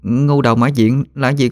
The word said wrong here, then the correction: ngưu: "Ngưu đầu mã diện cụ ơi, đọc là ngưu chứ không phải là ngu đâu.